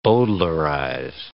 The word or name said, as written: bowdlerize